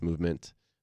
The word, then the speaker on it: MVMT: Movement Watches, it's spelled MVMT, but it's pronounced Movement.